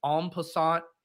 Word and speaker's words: en passant: Is that pronounced en passant? – It's a French term. – Yeah, I know it's fucking French, Liam. – Okay. I'm sure you knew that. – It fucking rhymes with croissant.